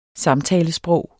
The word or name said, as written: samtalesprog